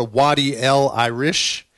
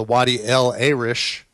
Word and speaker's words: Wadi El Arish: It's also known as the Wadi El Irish, or the Wadi El Arish. It's pronounced a few different ways, but it's a wadi or a small river.